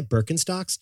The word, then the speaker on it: Birkenstock: But Snackers, if you buy Birkenstocks, it's because of a 300-year journey that got you to that decision. – 1774, a German cobbler named Johann Adam Birkenstock nailed the pronunciation.